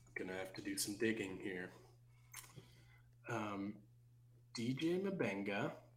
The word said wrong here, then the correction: Mbenga: I'm sorry, it's just DJ Benga. That is how you pronounce it, but it's spelled M-B-E-N-G-A.